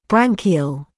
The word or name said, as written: branchial